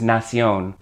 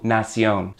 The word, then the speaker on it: nación: The word for nation in Spanish is nación. Same word basically, but not exactly. In this case, the T-I-O-N at the end of nation becomes a C-I-O-N with an accent on the O to give you nación.